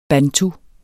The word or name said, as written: bantu